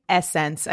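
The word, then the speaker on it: SSENSE: Essence. I think it's like a play on the word essence.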